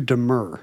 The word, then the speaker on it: demur: It's not demure. – I've been saying it wrong my whole life. The verb is to demur.